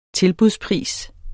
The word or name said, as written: tilbudspris